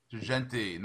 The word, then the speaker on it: Girgenti: Girgenti, nice Sicilian boy.